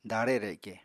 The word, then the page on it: daare reke: apatani